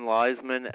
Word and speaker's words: Leizman: John Leisman, L-E-I-Z-M-A-N.